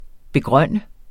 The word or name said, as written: begrønne